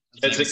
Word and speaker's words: Keswick: Keswick so with a silent W. – The silent W.